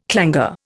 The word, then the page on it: clangour: Indian